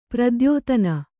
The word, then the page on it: ಪ್ರತಿಯಾತನ: prti yātana